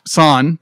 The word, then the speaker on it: San: And Son, who's the princess. – Yes. – San. Thank you.